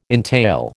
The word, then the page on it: entail: American English